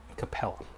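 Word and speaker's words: Capela: Clint Capola, is that how you say it? He's up too. I think he's a restricted free agent. So, I mean, they got some other moving pieces that, you know, they'd have to, Capella.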